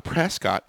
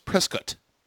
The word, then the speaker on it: Prescott: No, it's not Prescott. It's Prescott.